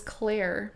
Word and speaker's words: Clare: My middle name is Claire. – I knew that. – And it's spelled C-L-A-R-E.